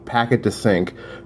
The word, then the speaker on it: Paquet De Cinq: This was their first locally released single from their debut EP, Paquet De Cinq, called Broken Halos.